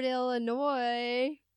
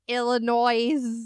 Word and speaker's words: Illinois: Harvard, Illinois. – Appreciate that silent S, my friend. – Illinois. – Nope. Got it.